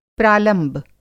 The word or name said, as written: प्रालंब